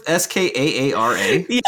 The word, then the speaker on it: Skaara: Skara, two A's in the middle there. Am I saying that right? – You don't like this character, do you? – Is that what? But it's Skaara.